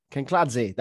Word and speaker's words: Kinkladze: Talking about Georgie Kinkladze, is it? I never say it. Kinkladze, that's how you say it.